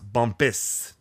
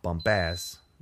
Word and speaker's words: Bumpass: So it's pronounced Bumpus, not Bumpass.